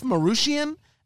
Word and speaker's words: Marootian: I think you pronounce his name, Jeff Marootian, M A R O O T I A N.